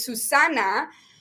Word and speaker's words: Susana: And so my full name is Susana, but it's S-U-S-A-N-A. And in English, it's just the last part, but in English. So Susana is Zana. In an English pronunciation.